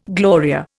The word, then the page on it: Gloria: Indian